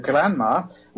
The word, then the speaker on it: Granma: If you read a biography of Castro, you know that in 1957, when Castro landed in the boat, the Granma, which, by the way, should be pronounced in the English term because it's actually an English word. It's later is all grandma is grandmother.